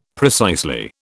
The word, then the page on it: precisely: British English